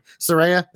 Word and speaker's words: Saraya: Or Saraya, I always say it wrong. Saraya. I don't know which one's which anyway. Saraya, that's the one actual way.